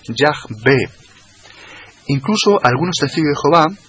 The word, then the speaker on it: Jehová: Se transforma en E, de tal forma que al meter la E, la O y la A sobre esas cuatro consonantes, quedó el texto leído pronunciado como Jehová, Jehová.